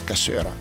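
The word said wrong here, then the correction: cassoeula: No, non si dice cassuera, lo dici solo tu, si dice cassuela, sei l'unico che lo dice con la R.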